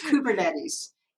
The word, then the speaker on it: Kubernetes: And so the adoption of cloud native technologies like microservices and Kubernetes, how do I pronounce this? Kubernetes? – Nobody knows how to say it. You know, nobody knows how to say it. It's Kubernetes. It's a Greek word.